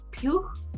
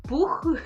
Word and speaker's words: Pugh: Se dice eh, Pugh, Pugh. Ah.